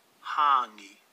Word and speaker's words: hangi: So for hangi, the correct Maori pronunciation is Honey, honey.